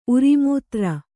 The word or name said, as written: ಉರಿಮೂತ್ರ